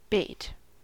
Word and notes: bait: American English

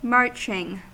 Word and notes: marching: American English

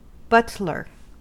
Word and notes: butler: American English

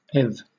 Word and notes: eth: British English

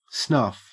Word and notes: snuff: Australian English

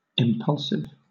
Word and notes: impulsive: British English

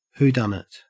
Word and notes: whodunit: Australian English

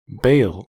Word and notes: bale: American English

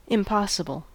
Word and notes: impossible: American English

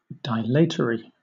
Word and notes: dilatory: British English

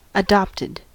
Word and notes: adopted: American English

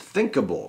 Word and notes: thinkable: American English

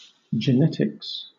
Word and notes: genetics: British English

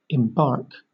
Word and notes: embark: British English

